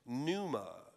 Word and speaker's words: pneuma: It's pronounced pneuma.